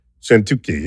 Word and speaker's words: Szentkuti: S-Z-E-N-T-K-U-T-I. – Sentuki.